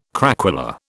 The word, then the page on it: craquelure: British English